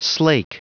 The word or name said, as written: slake